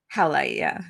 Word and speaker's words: howlite: And I was like, oh, can I just put this hemolite just under your, it's hemolite, right? – Howlite. – Howlite. I knew I was saying it wrong.